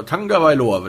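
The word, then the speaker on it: Tagovailoa: Ähm, man muss natürlich jetzt sagen, dass Tua Tua Tango, Tanga Wailoa, so Tanga Wailoa wird da ausgesprochen.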